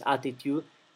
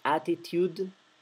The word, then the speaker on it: attitude: En inglés hagan lo posible por pronunciar claramente todas estas D finales. Attitude, ¿sí? No es attitude en, ¿verdad? Es attitude. Y luego la palabra I es end. Pronuncian bien claramente la D final.